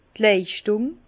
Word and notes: d' Leischtùng: Bas-Rhin; Herrlisheim

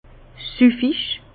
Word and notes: süffig: Bas-Rhin; Herrlisheim

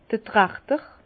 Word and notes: d'r Trachter: Bas Rhin; Herrlisheim

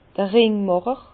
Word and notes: d'r Ringmàcher: Bas-Rhin; Herrlisheim

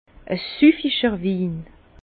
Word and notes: e süfficher Win: Bas-Rhin; Herrlisheim

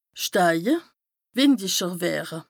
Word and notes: steije, wìndicher wëre: Bas-Rhin; Strasbourg

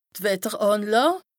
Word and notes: d' Wetterànlàà: Bas Rhin; Strasbourg

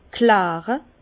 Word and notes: kläre: Bas-Rhin; Herrlisheim

